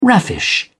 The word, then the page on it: raffish: British English